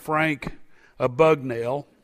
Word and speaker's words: Frank Abagnale: He's a man by the name of Frank Abagnale, is how you pronounce his name.